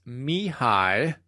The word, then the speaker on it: Mihai: According to Martin Seligman, the author of Authentic Happiness, and you can see the notes on that, it's Mihai is his first name, Csikszentmihalyi.